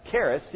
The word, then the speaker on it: charis: It's pronounced charis, C-H-A-R-I-S, in the Greek, but it means a gift, among other things.